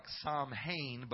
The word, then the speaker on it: Samhain: It's spelled like Samhain, but it's spoken Samhain.